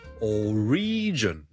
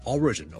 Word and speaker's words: origin: number two 咧，如果读成 origin 咧就会怪怪地啦，因为个重音其实 origin O-R-I-G-I-N 个来源咧，我哋个重音系第一个音节嘅，所以 single origin。